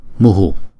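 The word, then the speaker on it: muḥ: Thì theo nguyên tắc thì chúng ta lấy chữ nguyên âm ngoài trước là chữ U này, chúng ta choàng ra ngoài sau. Chúng ta đọc là muhu, muhu. Nhưng mà trong câu chú này chúng ta đọc là mu, mu. Chúng ta hà cái hơi ra ngoài sau một chút.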